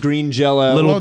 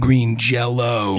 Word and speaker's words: Green Jelly: I think I got Green Jelly, or it's pronounced Green Jello. – Did you get it as Jello or Jelly? – It had a Y, but I think the thing with them is you always pronounce it Green Jello.